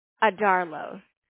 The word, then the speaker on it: Adarlo: Last name is A-D-A-R-L-O. – Adarlo. How you say it? – Adarlo.